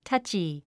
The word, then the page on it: touchy: tʌ́tʃi; タァチィ